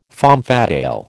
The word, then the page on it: femme fatale: American English